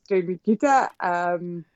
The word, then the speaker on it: Streimikyte: So my name is Greta, and my surname is Streimikyte.